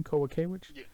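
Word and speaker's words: Kowalkiewicz: Kowakiewicz? – Yeah.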